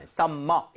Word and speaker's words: summer: M이 두개 있지만 이걸 굳이 이렇게 나눠서 발음하진 않아요. Summer 이렇게 하지 않습니다. Summer.